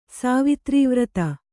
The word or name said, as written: ಸಾವಿತ್ರೀವ್ರತ